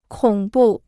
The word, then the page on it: 恐怖: kǒng bù